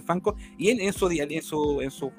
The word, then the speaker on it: Funko: Entonces es una sigla de fan corporation y de ahí por eso se llaman Fanco en inglés, pero se escribe funco Para nosotros siempre será Funko.